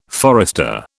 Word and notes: forester: British English

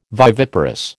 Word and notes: viviparous: American English

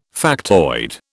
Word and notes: factoid: British English

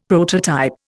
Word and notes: prototype: Indian

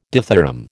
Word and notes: dithyramb: American English

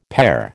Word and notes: pare: American English

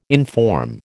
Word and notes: inform: American English

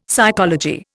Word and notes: psychology: Indian

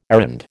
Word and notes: errand: American English

